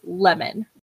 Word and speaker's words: Lehman: I realize it just sounds like I said lemon, like the fruit. – Like the fruit. – But it's spelled L-E-H-M-A-N. Like, how would you pronounce that? Is it just lemon? – Lemon.